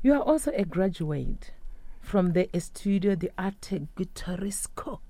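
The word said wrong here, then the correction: Guitarístico: Did I say that right? – Guitaristico.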